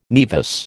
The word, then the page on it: naevus: American English